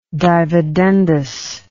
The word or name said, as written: dividendus